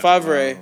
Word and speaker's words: Favre: Favre. – Yeah. Favre. Oh, and she, what was the other person name you fucked up? It was Brett Favre.